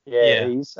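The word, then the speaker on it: Ys: I think it might be pronounced Ease. – Ease. I only learned that today, actually.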